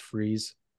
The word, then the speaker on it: Fries: It's spelled Fries, but it's pronounced Freeze, is the producer.